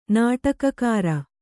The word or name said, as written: ನಾಟಕಕಾರ